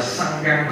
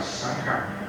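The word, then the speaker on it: saṅkhaṃ: Cái chữ này đáng lẽ đọc là Sankham mà, nhưng mà trong thực tế là vẫn đọc, các sư của mình vẫn đọc là sáng khắc.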